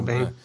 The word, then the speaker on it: Biehn: Is it Biehn? – Michael Biehn.